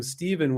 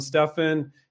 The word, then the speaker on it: Stephen: Stephen is her other co-founder, S-T-E-P-H-E-N, but everyone in the video seems to call him Stephen with a V. Yeah, he pronounces it Stephen. So Stephen is one of those names where it can be a P-H or a V, but a lot of people with the P-H still pronounce it with a V, like Stephen. So I don't call him Stefan. He doesn't want to be called Stefan.